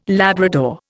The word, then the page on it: Labrador: Indian